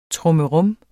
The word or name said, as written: trummerum